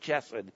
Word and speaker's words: chesed: It's a rough H. Sometimes it's spelled C-H-E-S-E-D, but then people pronounce it chesed, and that's not right. It's chesed. Our babies can use that sound.